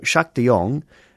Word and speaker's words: Sjaak de Jong: So Jacques de Jong, his name is spelt S-J-A-A-K-D-E-J-O-N-G. That's Dutch, Jacques de Jong.